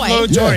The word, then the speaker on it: Pablo Torre: Your boy, Pablo Torre. Is that how you say his name? Your boy. – Pablo Torre, yeah.